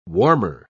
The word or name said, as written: warmer